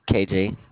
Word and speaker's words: KJ: KJ.